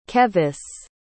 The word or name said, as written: kevis